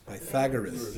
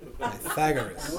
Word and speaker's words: Pythagoras: Pythagoras? – Pythagoras. – See? Pythagoras.